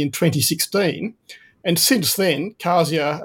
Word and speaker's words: Kazia: Today we're talking to the CEO of cancer drug developer Kazia Therapeutics.